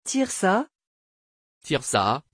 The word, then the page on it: tirza: French